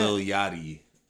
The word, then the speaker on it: Yachty: Her and little Yaki, or Yachi, excuse me. Yachty? How did you say that? – Lil Yachty.